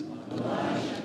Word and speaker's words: Elisha: Elisha. – This is not Elijah. This is Elisha.